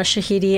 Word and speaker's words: Shahidi: Yara Shahidi is playing the female? – Yes, it is. Yara Shahidi and Charles Melton.